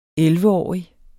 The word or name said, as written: elleveårig